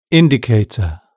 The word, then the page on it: indicator: British English